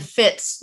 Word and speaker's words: Fitts: My name is spelled Sheri, one R, and fits like your shoe fits with two T's.